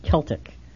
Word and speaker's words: Celtic: And during the break, Fred, you mentioned to me that Celtic is properly pronounced Celtic.